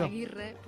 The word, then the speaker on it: Agirre: Está con nosotros eh, Yasone Agirre, ¿sí? ¿Está bien dicho? – Aguirre. Aguirre, ah. que se escribe sin la U.